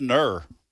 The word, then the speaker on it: gnurr: G-N-U-R-R. – How do you say that? – I guess ner. That's the way Mike pronounced it. – G-N-U-R-R.